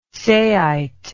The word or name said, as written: saite